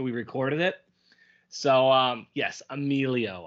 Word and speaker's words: Emilio: And I said Emilio Delgado's name wrong like a million times. His name is Emilio, and I was saying it Emil the entire time.